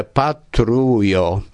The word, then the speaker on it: patrujo: Un exemple patrujo, do estas tri silaboj kaj la akcento estas la dua silabo antaŭ lasta silabo patrujo.